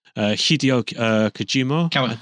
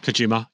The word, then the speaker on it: Kojima: Hideo Kojima. – Kojima.